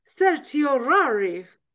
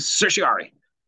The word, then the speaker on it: certiorari: And most people just really shorten it down to writ of certiorari. They bunch all those vowels together in R's. – All right. – Writ of certiorari. – Okay. – But there's actually a couple syllables in there, and I'm sure I bunched.